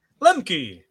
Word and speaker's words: Lemke: Lemke.